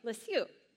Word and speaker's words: Lisieux: There is a woman, her name's Teresa of, I need someone who speaks French to pronounce this for me. I'm going to call Lisieux. There you go. Thank you. Lisieux.